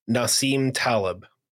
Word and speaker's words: Nassim Taleb: Nassim Taleb.